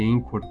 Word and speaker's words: Anchor: só para lembrar que Encor se escreve com ch barra caminho do produtor, por que, que eu digo isso?